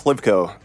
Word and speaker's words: Hlivko: Yeah, it's Miles Hlivko. So the H isn't, like, totally silent, but normally, like, if I'm going easy on people, I'll just say the H is silent.